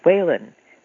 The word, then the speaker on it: Whelan: W-H-E-L-A-N is pronounced Whalen, the Irish way, because my husband's family were Irish.